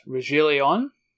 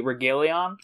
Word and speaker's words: Reguilón: Um, if I wanted to Americanize, it'd be Regalion, but pretty sure that's not it.